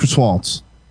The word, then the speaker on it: croissant: Croissants. It's pronounced croissants.